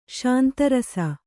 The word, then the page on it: ಶಾಂತರಸ: śanta rasa